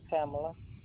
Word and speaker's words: Pamela: Sure, Pamela. – Can you spell it for me? – P-A-M-E-L-A. – You said T-A-M-E-L-A? P-A-M-E-L-A.